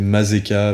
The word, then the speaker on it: Mazeka: Euh, c'est ça Ah non, je me suis trompé de, de Donc c'est peut-être moi qui ai mal noté ta C'est Mazeka avec un Z, K à la fin, comme ça se prononce,